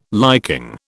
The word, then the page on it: liking: British English